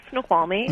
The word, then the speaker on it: Snoqualmie: A listener joins us now, in Snoqualmie, Washington. Did I say that right? – It's pretty close, Snoqualmie.